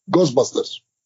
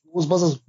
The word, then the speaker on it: Ghostbusters: Er sagte nicht Ghostbusters, er sagte Ghostbusters. Nach dieser polnischen Art. Mhm. Nicht Ghostbusters, Ghostbusters. Junge, hör auf, immer diese Ghostbusters anzumachen.